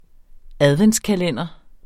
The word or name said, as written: adventskalender